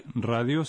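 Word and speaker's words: Radio (ラジオ): Y radio se pronuncia Radio. – De modo que la primera sílaba de radio se pronuncia como ra en la palabra pera.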